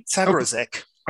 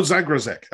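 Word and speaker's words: Zagrosek: Lothar Zagrashek, if that's how it's pronounced. – Yeah. – I mean, Zagrashek. – Oh, Zagrashek. Okay.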